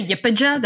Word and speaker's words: YPJ: It's the Yipija. That's how they pronounce it.